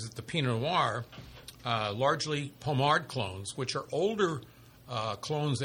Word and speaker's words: Pommard: Pommard. – There's a D at the end, but it's Pommard clone from Jane's Vineyard, which we are familiar with.